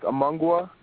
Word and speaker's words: Amungwa: My name is Amungwa Bonu. – How do you spell that? Amungwa? – Yeah, it's A-M-U-N-G-W-A.